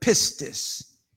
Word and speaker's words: pistis: The word for faith is the Greek word pistis, P I S T I S, if you want to spell it with, with our English characters.